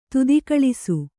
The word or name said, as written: ತೊತ್ತುಗೆಲಸ